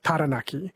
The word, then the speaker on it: Taranaki: Like, I noticed the rule, one that I've tried is for Māori pronunciation, is they don't have the a sound like cat. It's instead of Taranaki, it's Taranaki, Taupo.